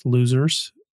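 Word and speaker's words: Lousers: That is my very first podcast, Losers. It's spelled like Lou, L-O-U, and then Zers, S-E-R-S, Zers.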